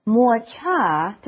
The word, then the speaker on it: 抹茶: 抹茶, did I say it right? – Yeah.